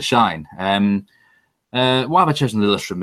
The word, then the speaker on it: Lillestrøm: is going to be Lillestrøm. Have I pronounced that correctly? – Yeah, Lillestrøm.